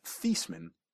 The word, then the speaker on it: Theismann: His name was originally pronounced Theismann, and he changed it to increase his odds of winning a Heisman.